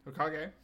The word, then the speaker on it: Hokage: Hokage.